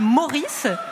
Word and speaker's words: Maurice: Alors, jeudi, sur l'île Meurice. – Non. Ouais. Non, vous prononcez mal. C'est l'île Maurice.